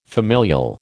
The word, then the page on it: familial: American English